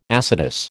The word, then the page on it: acetous: American English